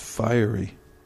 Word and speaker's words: fiery: I believe it's pronounced fiery. If I'm not mistaken, it's fiery energy. It looks like fury, but it's fiery energy.